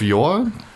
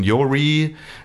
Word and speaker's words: Yore: Yori, ja, Patrice Scott sagt immer Yori und ich sage so, naja, Yor, es wird ausgesprochen wie Yor, also wie deins, ja.